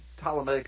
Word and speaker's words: Ptolemaic: And the Seleucid dynasty and the Ptolemaic dynasty, and Ptolemaic is spelled with a P-T-O-L-E-M-C, so it's pronounced Ptolemaic dynasty,